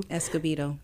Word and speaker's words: Escobedo: Escobedo.